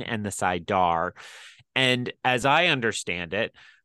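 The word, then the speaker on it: saidar: Sidar is correct.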